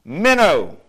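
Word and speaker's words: Minnow (μένω): Now the word continues, translated from a Greek word pronounced this way. Minnow. Like you go fishing and you use a little piece of bait and sometimes you'll put a minnow on your hook to try to catch a fish. A minnow, that's the way it's pronounced.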